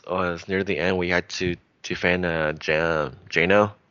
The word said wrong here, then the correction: Juna: Is that how you pronounce it? Juna. – Right, Juna. I'm thinking O at the end.